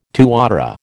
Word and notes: tuatara: American English

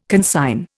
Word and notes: consign: Indian

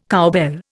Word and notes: cowbell: Indian

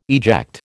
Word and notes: eject: American English

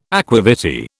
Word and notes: aqua vitae: British English

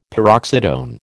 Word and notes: paroxytone: American English

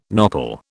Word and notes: nopal: British English

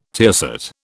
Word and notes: tiercet: British English

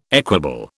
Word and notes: equable: British English

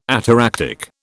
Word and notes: ataractic: British English

